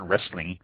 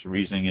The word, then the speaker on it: Riesling: So we are going to talk about the Riesling grape, spelled R-I-E-S-L-I-N-G, Riesling. I've heard some people pronounce it wrestling, but the Riesling is the pronunciation I have always heard, I've always used, and everyone I've ever talked to in the industry has always used it.